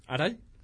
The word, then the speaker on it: Arai: ¿Ese se pronuncia? – Arai.